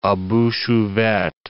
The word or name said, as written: a bouche ouverte